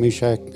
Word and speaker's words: Meshach: When Pastor Lee started the meeting and he said, this feels like Shadrach, Meshach and Abednego.